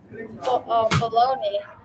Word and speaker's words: Bologna: Also, Lazio losing 1-0 against, or 3-0 actually against Bologna, or is how we Americans pronounce it. – Yeah, Bologna.